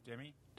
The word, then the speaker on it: Demi: How do you say the D-E-M-I? – Demi.